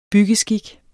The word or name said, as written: byggeskik